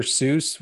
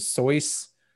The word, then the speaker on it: Seuss: the name Seuss is not pronounced Seuss. I was told this. I don't know this definitively, but I was told this by a friend that Seuss was actually actually pronounced as Soyce.